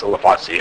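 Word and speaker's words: Sulawesi: A malleable bird lives in, I think it's pronounced Selawesi, anyway, someplace near Indonesia.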